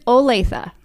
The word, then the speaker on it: Olathe: I've heard Olathe. – Say it again. – It is Olathe.